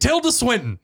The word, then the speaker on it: Tilda Swinton: Tilda Swinton.